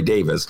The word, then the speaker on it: Davies: I've been mispronouncing Ray Davies' name. It's Ray Davis.